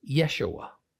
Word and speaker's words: Yeshua: Pronouncing it phonetically, one way is Yehoshua, the other way is Yeshua.